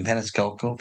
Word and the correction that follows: Fasciano: Fasciano. That's it. Fasciano.